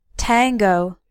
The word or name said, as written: tango